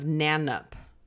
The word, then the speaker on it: Nannup: At the end of 2003, Simon, Chantel, Leela, and Tony moved into a farmhouse in the remote town of Nanup. And I know I've said that right because I've heard that town name a lot. And it's spelled N-A-N-N-U-P. It's not that hard to say.